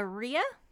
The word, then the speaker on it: rhea: It's a rhea, R-H-E-A. – I think that's correct. I mean, that's how I would say it.